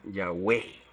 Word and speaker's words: Yahweh: Evidencia externa, o más o menos así dice, muestra que se pronunciaba Yahweh.